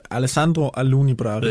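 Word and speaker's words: Alessandro Alunni Bravi: Det er jo din manager der, Bravi, er det rigtigt udtalt? – Alessandro Aluni Bravi.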